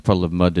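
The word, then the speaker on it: Puddle of Mudd: Both pronouncing it wrong. It's Puddle of Mud. – Puddle of Mud. – It's two D's at the end.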